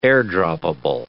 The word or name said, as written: air-droppable